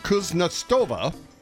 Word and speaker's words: Kuznetsova: During a women's tennis match in London, between two athletes I'm going to attempt to pronounce, Agnieszka Radwanska and Svetlana Kuznetsova.